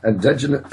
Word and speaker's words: endogenous: But what I do want to move on next to, if I can, please, gentlemen, is the issue of endogenous, excuse me, endogenous, is that how you pronounce it? Endogenous retroviruses. Excuse me. – Endogenous. – Thank you. I knew that was, I was getting it wrong. Endogenous.